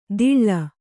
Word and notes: ದಿಳ್ಳ: diḷḷa

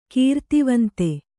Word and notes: ಕೃತಿವಂತ: křtivanta

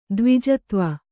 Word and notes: ದ್ವಿಜತ್ವ: kannada

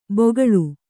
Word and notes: ಬೊಗಳು: bogaḷu

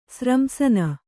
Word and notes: ಸ್ರಂಸನ: sramsana

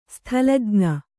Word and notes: ಸ್ಥಲಜ್ಞ: sthalajña

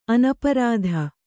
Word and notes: ಅನಪರಾಧ: anaparādha

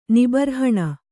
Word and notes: ನಿಬರ್ಹಣ: kannada

